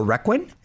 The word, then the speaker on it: Requin: And you also starred in The Requin. Is that how you pronounce it? – Yes.